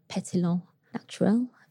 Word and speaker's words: pétillant naturel: A pet nat is a pétillant naturel, which is yes. – the correct pronunciation.